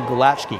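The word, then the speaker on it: Gulácsi: The goalkeeper, Paul Lukaski, or Gulkowski. Actually, I'll say Gulacki, because that's the correct way to say it.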